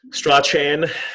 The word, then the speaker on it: Strachan: Look, Jake, is it Jake Strachan? – Strachan. Yeah, no one knows how to say his name. – I think Jake Strachan, if you're going off the Scottish pronunciation.